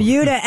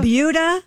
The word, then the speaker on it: Buda: It's Buda? – Buda. – Buda. Skip over. – Buda. – It's spelled B-U-D-A, and I looked it up. It is pronounced like beauty.